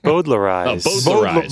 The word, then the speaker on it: Bowdlerize: Bowdlerize.